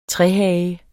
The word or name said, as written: trehage